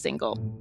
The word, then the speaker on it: Singal: That's Singal spelled like Singal, S-I-N-G-A-L, but pronounced single.